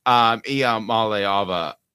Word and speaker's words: Iamaleava: Iamaleava.